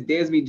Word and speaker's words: Dezbee: My name is Dezbee John Trail McDaniel.